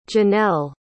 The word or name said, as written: jinelle